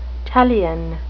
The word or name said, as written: talion